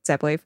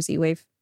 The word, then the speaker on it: Z-Wave: They're using Zepwave or Z-Wave, depending on your pronunciation.